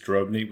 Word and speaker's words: Drobny: And the pronunciation is Drobny with a strong O. Very important.